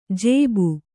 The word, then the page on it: ಜೇಬು: jēbu